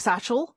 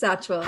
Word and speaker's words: Satchwell: I keep feeling the urge to pronounce it like chisel. – Yeah, I know. – Like satchel. But is it Satchwell? – It's Satchwell.